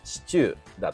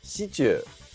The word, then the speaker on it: シチュー: のシチューですね、えー、こちらのイントネーションを掘り下げていこうじゃないかということでね、まあ、早速ですが、えー、正しいイントネーションはシチューだと思います最初の「し」にアクセントが来て「中」で下がるんだよね、えー、しかししかしながら私たちダモンデ県民はですね「シチュー」と発音しますシチューですねえー、死にアクセントが来るものの、中の部分で上がってしまうんですよね。